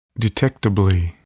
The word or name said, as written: detectably